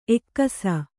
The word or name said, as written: ಎಕ್ಕಿಸು